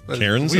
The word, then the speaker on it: Cairns: I think it's Cairns. – We pronounce it Cairns, C-A-I-R-N-S.